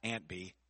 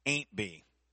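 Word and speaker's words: Aunt Bee: Well, not Aunt B. Ain't B. That's how they say it on the show.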